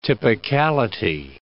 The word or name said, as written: typicality